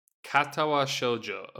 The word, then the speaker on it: Katawa Shoujo: Is Katawa Chojo. – Okay. – Katawa Shoujo. Okay.